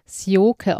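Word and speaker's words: Sjoeke: Ich finde Sioike Nuskin, man soll sie ja Sioike aussprechen.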